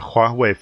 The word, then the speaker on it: Huawei: Now, Roger, the UK government has banned Huawei from its 5G telecom network, and national operators must complete the removal of all Chinese technology by 2027.